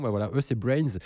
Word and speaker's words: BRNS: On prononce Brains.